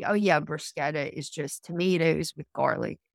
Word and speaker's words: bruschetta: But, you know, and there's something called bruschetta, which Americans pronounce it bruschetta because there's a CH, but it's really called bruschetta.